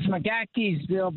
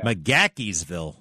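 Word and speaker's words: McGaheysville: What is this? McKaysville? McKaysville? – It's McGackiesville, Virginia. – McGackiesville.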